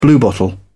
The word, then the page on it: bluebottle: British English